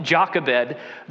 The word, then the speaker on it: Jochebed: the mother named Jochebed. Yeah, you're going to hear a sermon, perhaps for the first time in your life, on Jochebed, the mother of Moses.